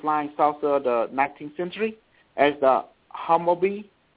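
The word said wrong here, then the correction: Haunebu: Hanabu, yes.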